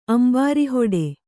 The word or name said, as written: ಅಂಬಾರಿಹೊಡೆ